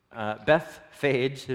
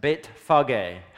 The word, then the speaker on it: Bethphage: As they approached Jerusalem and they came to Bethphage, is how we butcher it in English, but Beit Phage, house of the early fig.